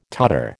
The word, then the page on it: Tatar: American English